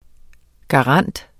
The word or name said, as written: garant